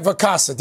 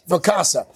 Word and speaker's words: Vacasa: Vacation rental site Vacasa. Did I say that right? – Yes. – Vacasa.